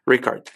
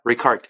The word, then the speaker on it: Ricard: Her name is Charlie Richard or Ricard. I never know how to properly pronounce her name. – Ricard. – There you go. It's a K, not a ch. Ricard.